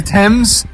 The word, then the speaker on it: Thames: The Thames. – Yeah, let me teach you about London. They don't like pronouncing the letter H.